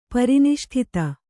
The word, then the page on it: ಪರಿನಿಷ್ಠಿತ: pari niṣṭhita